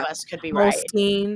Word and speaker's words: Moleskine: Is that how you say it, Moleskine? – I think it's Skeen, but I could be wrong.